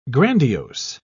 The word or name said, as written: grandiose